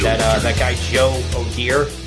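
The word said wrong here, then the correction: O'Dea: Is that how you say it? O'Day or Deer? Yeah, O'Day.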